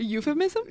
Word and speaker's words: euphemism: How do you say that word? – A euphemism? – Yeah, thank you.